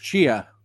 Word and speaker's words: Chia: It's just Chia, like Chia Pet.